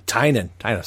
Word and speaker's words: Tynion: And we just saw that an homage to that recently last year in James Tynion's Tynion. – Said it wrong.